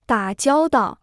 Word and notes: 打交道: dǎ jiāo dào

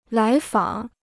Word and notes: 来访: lái fǎng

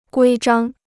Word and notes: 规章: guī zhāng

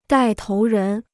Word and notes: 带头人: dài tóu rén

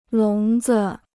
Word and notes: 笼子: lóng zi

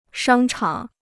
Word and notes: 商场: shāng chǎng

